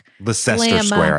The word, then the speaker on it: Leicester Square: Leicester Square, I think, is how you say it, but yeah. – Yes, that's right. Square. – Leicester. – Uh-huh. Leicester Square. – Leicester Square. – You might know it as Leicester Square.